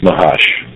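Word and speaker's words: Mahash (מהש): The fifth name, Mem-Heh-Shin, is frequently pronounced Mahash.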